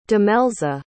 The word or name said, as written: demelza